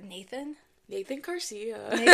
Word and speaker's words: Garcia: Um, I mispronounced uh, Garcia. How did you say it? Uh, Garcia. Carcia. Carcia. I didn't see the thing on the G, and so I said Carcia.